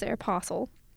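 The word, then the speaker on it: Aéropostale: My mom says Aeropostale. The T is silent.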